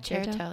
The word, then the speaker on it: Cherto: Yep. Cherto. Like a chair is on your toe.